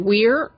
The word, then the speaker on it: Weir: Is it Weir or Wire? – I think Weir. it's Weir. Weir. I think it's Weir, yeah. Okay, that's what I thought. – And Andy it's funny, Weir.